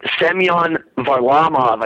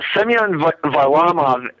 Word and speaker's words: Semyon Varlamov: A lot of fans, and I'll try and be brief here, but a lot of fans were very high on Semyon Varlamov. I said it right. Yeah, Semyon Varlamov,